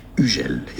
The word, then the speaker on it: Hugel: Hugel is the French pronunciation. That's the sound of the letter U. U, very French. The H is silent. And then Gel, not Hugel. Hugel.